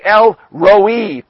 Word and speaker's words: El Roi: As the next name that's given is El Roy, or as I've been listening to some of these that know it a bit better, they say El Roi, possibly is a good pronunciation as well.